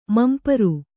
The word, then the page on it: ಮಂಪರ: mampara